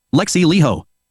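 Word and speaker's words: Alexi Laiho: Alexis Leo, Finnish pronunciation. Lexi Leho.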